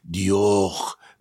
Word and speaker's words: Dior: Dior, mit diesem gehauchten R.